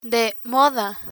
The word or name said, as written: de moda